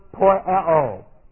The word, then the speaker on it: poieo: It's a future active indicative of the Greek word poieo. Here it is. P-O-I-E, long O. Poieo, it's exactly what it means.